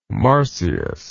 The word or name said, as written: marsyas